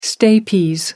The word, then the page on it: stapes: American English